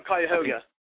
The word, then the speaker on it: Cuyahoga: Oh, Cuyahoga.